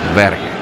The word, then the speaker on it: Berge: es que nosotros mencionamos su nombre como Berge. Y él se queja, no, o sea, no me desagrada, ¿no?, como lo pronuncien, pero la pronunciación correcta es Berge.